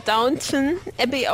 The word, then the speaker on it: Downton Abbey: Ja, ich schätze mal, das spricht man eher Downton Abbey aus, weil das ist ja nicht Downtown, wie das Restaurant in Gera mit 2 Ws, sondern nur ein W am Anfang.